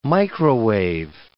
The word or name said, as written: microwave